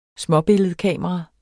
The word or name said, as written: småbilledkamera